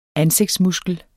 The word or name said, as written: ansigtsmuskel